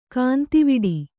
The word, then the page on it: ಗಂದವುಡಿ: Kannada